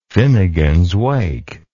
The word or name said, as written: finnegans wake